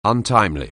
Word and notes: untimely: anglais

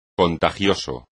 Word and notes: contagioso: espagnol

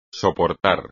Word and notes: soportar: espagnol

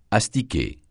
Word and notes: astiquer: francais

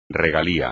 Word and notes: regalía: espagnol

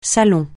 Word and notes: salon: francais

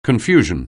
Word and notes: confusion: anglais

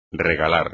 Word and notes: regalar: espagnol

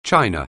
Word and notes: China: anglais

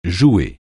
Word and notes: jouer: francais

